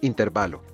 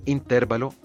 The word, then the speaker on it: intervalo: Este sustantivo es una palabra grave terminada en vocal, por lo tanto se escribe sin tilde, es decir, intervalo. De ahí que el diccionario panhispánico de dudas considera errónea la pronunciación y la grafía como esdrújula, por lo cual decir o escribir intervalo está mal.